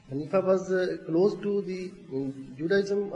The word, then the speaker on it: Hanifa: Because Hanifan is the word, because when it's used in when it happens in the end, then you don't pronounce the tanween, that is noon part. So this is why it's called Hanifa.